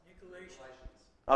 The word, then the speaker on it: Nicolaitans: Nicolaitans.